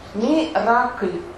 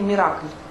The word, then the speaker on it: Миракль: Скажите слово, как правильно. – Миракль. Ну, в нашем русском произношении Миракль. По-французски там по-другому немножко.